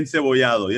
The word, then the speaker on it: encebollado: We got the bistec, how do you say it, encebollado? – Bistec encebollado, yes sir.